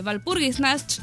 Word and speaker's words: Walpurgisnacht: Este tema es de Rufus Ufal, estuve practicando para pronunciarlo, es Walpurgisnacht, en alemán.